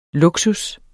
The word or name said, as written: luksus